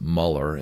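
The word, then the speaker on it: Müller: George Mueller, he's technically pronounced Muller in English.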